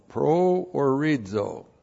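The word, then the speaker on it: proorizo (προορίζω): Looks like that in the Greek, P-R-O-O-R-I-Z, long O, proorizo.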